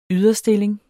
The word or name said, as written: yderstilling